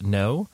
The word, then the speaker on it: Neaux: And next week's guest is Sierra Kusterbeck, who uh, plays in a band called Neaux.